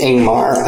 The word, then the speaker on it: Ingvemar: Yeah, it's spelled I-N-G-V-E-M-A-R, but I think it's pronounced Ingmar. I don't know where the V comes from.